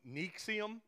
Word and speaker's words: NXIVM: And there was one, you see it listed there, NXIVM, that is not how it's pronounced. I think it's something like Nixium is how they pronounce it.